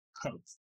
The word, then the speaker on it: Kvothe: Spelled K-V-O-T-H-E. It is supposed to be sort of Yiddish. – They say he mentions it's like it's pronounced like Quoth. – Yeah, it's supposed to be kind of like Quoth, but there's a literary sense and a spoken word sense to it too.